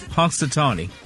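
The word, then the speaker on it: Punxsutawney: Hunks, Hunksatony.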